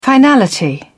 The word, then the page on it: finality: British English